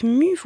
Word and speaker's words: MUES: Pour avoir une idée un petit peu du collectif MU, vous pouvez retrouver une interview, donc ça s'écrit M-U-E-S,